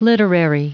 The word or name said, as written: literary